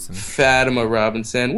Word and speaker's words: Fatima Robinson: Directed by Fatemita Robinson. Did I say her name right? Fatima Robinson.